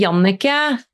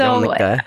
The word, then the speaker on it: Jannecke: In Norway, it's actually Jannecke. So Jannecke.